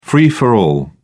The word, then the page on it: free for all: British English